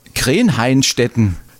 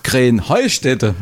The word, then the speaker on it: Krähenheustätte: Ja, ihr saget immer Krähenhainstetten. Und dabei heißt es doch Krähenheustätte, oder wie? – Krähenheustätte. – Ja, genau, und das die Betonung auf dem Heu.